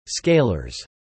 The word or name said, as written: scalers